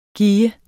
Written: gis

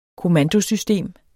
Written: kommandosystem

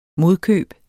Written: modkøb

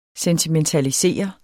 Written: sentimentalisere

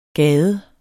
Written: gades

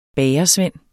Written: BAGERSVEND

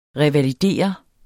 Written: revalidere

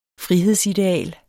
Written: frihedsideal

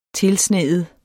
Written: tilsneet

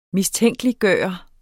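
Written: MISTÆNKELIGGJORTE

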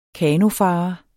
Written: kanofarer

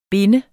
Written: binde

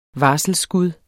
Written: varselsskud

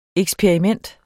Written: eksperiment

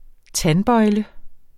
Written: tandbøjle

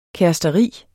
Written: kæresteri

